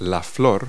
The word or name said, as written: flower